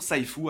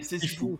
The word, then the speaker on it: Sifu: Depuis le début, début, début, début de l'année, on prononce toujours Saifu alors que c'est Sifu.